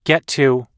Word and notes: get to: American English

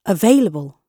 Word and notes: available: British English

